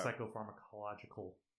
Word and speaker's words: psychopharmacological: Psychopharmacological. I'm saying that right.